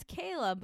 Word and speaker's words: Keila: I know my name is spelled K-E-I-L-A, and people say Keila. I'm like, no, it's Kayla, but it's spelled in Spanish because I'm from Puerto Rico.